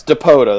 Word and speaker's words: Dipoto: Jerry DiPoto? – DiPoto, the old Angels general manager. – Yes, DiPoto, that's how you say it.